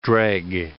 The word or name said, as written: dreg